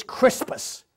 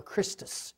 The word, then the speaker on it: Christus: I am tired of the argument over this Crispus. What, he mispronounces it. It's Christ or Christus.